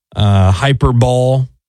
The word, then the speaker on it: hyperbole: Hyperbole. People say that. – Oh, hyperbole. – Yeah, hyperbole. – That's spelled. – Oh. Yeah. – Yeah, hyperbole. – But like, why would you, like, if you're going to say hyperbole, why would you even use the word? – I thought that was people mispronouncing hyperbolic.